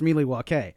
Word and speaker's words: Milwaukee: It's Algonquin for the good land, and it's pronounced Milwaukee.